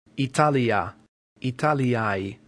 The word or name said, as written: italia